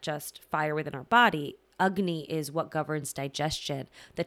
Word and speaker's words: Agni: So our conversation needs to start with Agni, and that's spelled A-G-N-I, but the A takes the U sound, Agni.